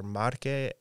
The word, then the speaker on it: Marche: So my grandparents are from the provinces are Marche and Abruzzo, which are they're kind of neighboring provinces.